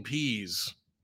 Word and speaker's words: Pees: I'm not just pronouncing it for the comedy. His name is P-E-E-S. That's 73 years of pee jokes for this poor guy. I bet during pregame collisions, Dean Pease.